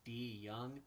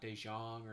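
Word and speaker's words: De Jong: His name is pronounced Chase D. Young. It's not DeJong or anything. It's D. Young. That's how he likes it pronounced.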